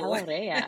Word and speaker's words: Palarea: Is it Palarea or Palarea? – Yeah, Palarea. But if you want to be authentic, I'll take Palarea. – Ooh, Palarea.